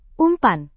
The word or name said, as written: umpan